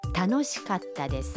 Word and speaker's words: 楽しかったです: در کلمه کاتا بین کا و تا یک مکس خیلی کوتاه داریم، یعنی در واقع صدای ت با تشدید تلفظ میشه. – تانوشی کاتا دس، すごく楽しかったです。